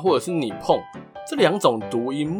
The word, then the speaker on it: 日本: 例如大家熟悉的日本银行和日本邮政这两个，他们名字中的日本就只能念作你碰。